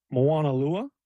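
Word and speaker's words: Moanalua: Moana Lua.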